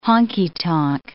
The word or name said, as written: honky-tonk